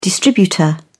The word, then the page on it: distributor: British English